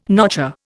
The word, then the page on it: notcher: Indian